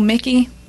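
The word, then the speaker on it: Michie: You know Mickey? – Yes. – But it's spelled Mitchie? – Yeah. – I didn't learn that until, and I had worked in Mickey for several years. It probably was like 2-3 years ago I realized I was saying it wrong the entire time.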